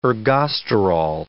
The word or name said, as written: ergosterol